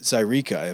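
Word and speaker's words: Zaireeka: You're coming off of Zyreka.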